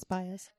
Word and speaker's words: Baez: Baez? – Yes, Baez.